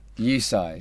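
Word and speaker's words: EUSci: USAI.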